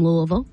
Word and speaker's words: Louisville: So JJ and Connie, they're from Louisville, Kentucky.